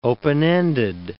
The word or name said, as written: open-ended